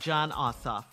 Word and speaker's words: Ossoff: We want to vote for Reverend Raphael Warnock and Senator and John Ossoff, okay? – You said it right, Senator.